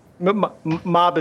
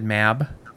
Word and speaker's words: Mab: You say mob? I said Mab. You probably also say oyster or erster. – I always pronounce it mob. Dialect. – Is it Mab? – I've always said Mab.